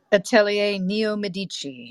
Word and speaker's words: Atelier Neo Medici: Am I saying that right? – Mm. Atelier Neo Medici, yes.